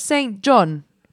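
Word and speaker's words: Saint JHN: Have you heard of Saint John? But it's spelled Saint and then JHN.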